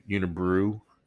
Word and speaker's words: Unibroue: i had butchered the pronunciation on unibrow uh, un- unibroue is uh, I believe that's the correct pr- correct pronunciation. Thank you, Quebec Quebecians, and uh, I promise I'm not having a stroke here.